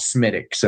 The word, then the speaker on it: Smithwick's: In English, it's Smittics. Smithwick's. It's Smithwick's. So if you've ever seen what we would say is Smithwick's, it's actually an Irish red called Smittics, and that's the way they pronounce it.